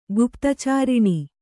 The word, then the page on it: ಗುಪ್ತಚಾರಿಣಿ: guptacāriṇi